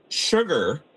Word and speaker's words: sugar: Sugar is a common word in which the first letter S is pronounced as sh, S-H.